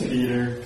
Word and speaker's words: Peder: P-E-D-E-R. – How do you pronounce it? – Okay. – Just Peter. Peter. Just the same, pretty much. – How do you pronounce your last name? – Schaefer. – Schaefer. – S-C-H-A-F-E-R.